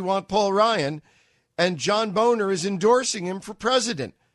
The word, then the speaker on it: Boehner: John Boehner, pronounced Boehner, the former Speaker of the House, has provisionally endorsed Paul Ryan for President of the United States.